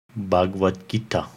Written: Bahagavat Gita

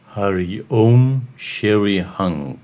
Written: Haree Om Shrii Hung

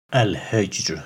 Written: Al-Hijr

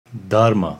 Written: Darma

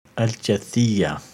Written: Al-Jathiyah

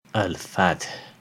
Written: Al-Fath

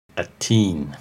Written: At-Tin

